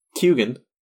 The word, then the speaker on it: Keoghan: But yeah, I watched interviews where he literally says it's pronounced Keoghan or something. Mm-hmm. I think it's Keoghan. He says that the G is in there. He pronounces the G.